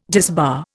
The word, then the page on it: disbar: Indian